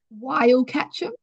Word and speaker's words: Wyalkatchem: My mum's family has farmed in a little town called Wyalkatchem, which is about two and a half hours from Perth. – Sorry, can you repeat the name again? Wyalkatchem.